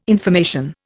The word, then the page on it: information: Indian